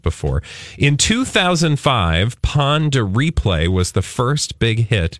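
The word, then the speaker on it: Pon de Replay: How do you say this last one? – Pond a replay? – Pond a replay? – Yes. – Like Ponderosa? – Yes.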